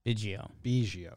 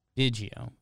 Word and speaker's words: Biggio: His dad's has Biggio. Biggio. – It's Biggio, right? – Biggio, yeah. – Do you have a take on this, BBD? – I lean Biggio. – Okay. I think that's right.